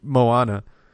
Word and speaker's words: Moana: How is it pronounced? – It's Moana. – Oh, Moana.